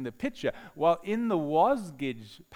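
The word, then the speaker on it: Wasgij: Wasgidge is the word jigsaw pronounced backwards.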